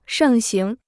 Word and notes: 盛行: shèng xíng

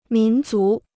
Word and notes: 民族: mín zú

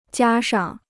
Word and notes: 加上: jiā shàng

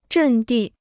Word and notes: 阵地: zhèn dì

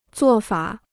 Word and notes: 做法: zuò fǎ

